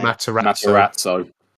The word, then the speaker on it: Matarazzo: Closely followed by Gaten Mazzarazzo. However you say his name. – Matarazzo. – That's it. There you go.